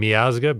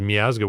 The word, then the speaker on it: Miazga: And the last name, I pronounce my last name Miazga, because in Ukrainian, as in Polish and other European languages, there's no long I sound. And after living in Ukraine for a while, I went back to pronouncing it as Miazga, whereas everyone else in my family to this day still has it as Miazga.